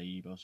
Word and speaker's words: Igbo: After doing that episode, I said Igbo. And I was watching some interviews with Chinua Achebe, who wrote the book, and I heard him say Igbo, so I realised I've been saying it wrong.